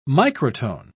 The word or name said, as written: microtone